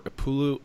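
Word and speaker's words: Upolu: And so the South Pacific tribes were Upulu, I think that's how you pronounce it, and Savai'i.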